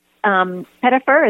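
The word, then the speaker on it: Pettyfer: And Alex Pettifer, is that how you say his name? – That is, yeah.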